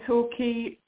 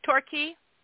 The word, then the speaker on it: Torquay: in Torquay, Devon, UK, which is T-O-R. – Okay, wait, where was that? What time was that again? I'm sorry. – 4:25 a.m. Sorry, 4:25 a.m. – Mm-hmm. Torquay. – Yeah, Devon, which is in the UK, and it's spelled T-O-R-Q-U-A-Y. – Q-U-A-Y, yep.